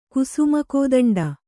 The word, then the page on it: ಕುಸುಮಕೋದಂಡ: Kannada